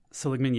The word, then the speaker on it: Seligman: I don't know how to pronounce this, Mike Seligman. Seligman, yeah. That's not surprising because that area was was was was quite wet.